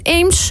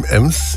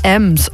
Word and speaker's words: Ems: Kinderrommelmarkt, streekproductenmarkt uit het gebied tussen Runde en Eems. Of Ems. Is het nou Eems of Ems? Hoe spreek je dat nu in Duits? In Ems. – Ems. Oh ja, und wir sagen Ems.